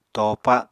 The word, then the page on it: topa: Apatani